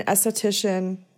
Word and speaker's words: esthetician: So he's just like a skincare YouTuber, but he's not like an esthetician.